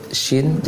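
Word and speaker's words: Shi (شِ): Ya, ingat dalam pengucapan huruf Shin, jangan sampai terlalu ke depan bibirnya. Bahkan kalau bisa diusahakan bibirnya tidak monyong ke depan. Shi, tidak jauh beda dengan ketika membaca hamzah kasrah. I, shi.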